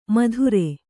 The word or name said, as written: ಮಧುರೆ